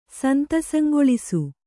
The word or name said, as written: ಸಂತಸಂಗೊಳಿಸು